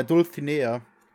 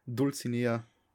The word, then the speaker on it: Dulcinea: Dulcinea. Ja, ich kann das so nicht aussprechen, aber das ist die richtige Aussprache. Dulcinea ist die falsche, aber ich, ich nenne es so.